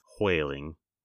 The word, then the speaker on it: whaling: And pronounced with an H at the front. Whaling. – Yeah, all of the WH words are in Mid-Atlantic English, supposed to be pronounced with the H first.